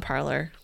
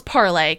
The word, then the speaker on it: Parler: No, we're not on Parler. No. – also, it's fucking pronounced parlay.